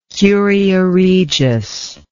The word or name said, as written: curia regis